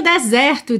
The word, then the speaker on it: desert: Bom, o deserto se escreve exatamente como o verbo desertar ou abandonar, D-E-S-E-R-T, D-E-S-E-R-T, mas a sílaba tônica é a primeira e esse E é aberto, então a gente fala desert, desert.